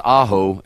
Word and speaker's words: Ajo: You know, Ajo, Arizona is a little town that's 127 miles from nowhere, spelled A J O, pronounced Aho, and translated means garlic.